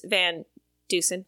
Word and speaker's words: Van Dusen: So Chris Van Dusen, did I say that right? – Yes.